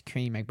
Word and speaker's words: Creme Egg: I know people are getting like, it's pronounced cream egg, but it's spelt creme. So. We all know how to spell cream, and we all know how to spell creme, all right? It's a creme egg.